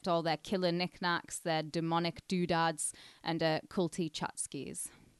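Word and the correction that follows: tchotchke: Did I say that right? We don't say the word tchotskis here. – I thought it was a tchotchki.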